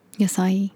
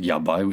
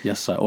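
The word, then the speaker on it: Yasai (野菜): Yasai. I honestly said yabai, which is terrible. Yasai.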